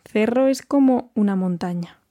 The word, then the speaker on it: Cerro: Yeah, una montaña, and it's C-E-R-R-O. So in Spain Spanish, we're pronouncing that as Cerro, but if you are more used to Latin American Spanish, then of course you would hear el Cerro de Santa Lucía.